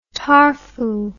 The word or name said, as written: tarfu